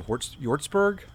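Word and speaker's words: Hjortsberg: I think his name's William Yortzberg. The H is silent. HJ.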